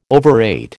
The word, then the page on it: overate: American English